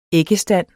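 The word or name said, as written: æggestand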